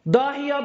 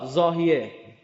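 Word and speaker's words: ضاحیه: داهیا به انگلیسی زاد، زاهیه. این ز و انگلیسی د تلفظ میکنن، مثل رمضان. رمضان دیدیم مثل رمضان.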